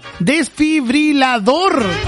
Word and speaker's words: desfibrilador: Desfibrilador. Desfibrilador. Esa palabra no me puedo acordar cómo se dice. Dije, ¿cómo fue que dije yo? Desfibrilizador, algo así. Y la palabra correcta es desfibrilador.